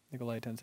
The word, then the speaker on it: Nicolaitans: Nicolaitans, that's how you pronounce it.